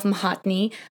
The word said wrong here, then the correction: Mohanty: Um, uh, I'm so sorry, Gourav, if I s- said that wrong. Mohan, Mohanty, thank you, Mohanty.